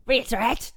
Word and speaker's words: reiterate: Is that a word? I said it right? – Cool. What did you say? – Reiterate? – Reiterate. – Say it again. – Re. It. – It. – All right. – Erate. – There we go. – Reiterate. – There we go. – Thank you. reiterate. – You were close. I think you had the syllables just like mixed up.